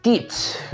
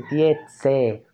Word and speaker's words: Tietze: Al principio yo decía, Stitch, porque sonara como más o menos, más o menos agringado, pero después me dijeron, no, weón, es se dice Tietze, tal como se escribe.